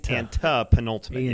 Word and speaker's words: antepenultimate: Not anti. It's antepenultimate. – A-N-T-E. – Yeah. Yes. Who, why do these words exist? – I spelled it out, too. A-N-T-E-P-E-N-U-L-T-I-M-A-T-E. – Yes. – Antepenultimate. – E-I-E-I-O.